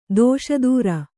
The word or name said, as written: ದುಸ್ತರ